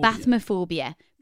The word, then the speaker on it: bathmophobia: Bathmophobia, pronounced as it sounds.